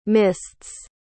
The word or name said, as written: mists